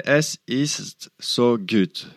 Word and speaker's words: Es ist so gut: Es ist so gut voilà avec la bonne prononciation